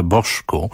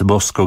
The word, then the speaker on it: Bosco: Pewnie się go wymawia Boszku, jakby to w Brazylii wypadało, ale wtedy już nie ma tej frajdy. Więc Bosco grał i śpiewał Bosco, własny zresztą utwór.